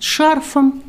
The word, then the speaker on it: шарф: Ну, скажу вам, что вот в этом словаре и во множестве других слово «шарф» рекомендуется нам произносить без подвижки ударения. То есть шарф без шарфа, с шарфом и так далее.